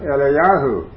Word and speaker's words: Eliyahu: And so therefore, I can also claim that title, Elijah, which in the Hebrew is pronounced Eliyahu.